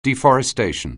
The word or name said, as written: deforestation